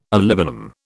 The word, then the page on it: olibanum: British English